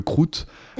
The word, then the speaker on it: crouth: Alors j'ai regardé, c'est rigolo, ça s'appelle le croûte. – ça, ça s'écrit C-R-O-U-T-H, et le truc marrant c'est que...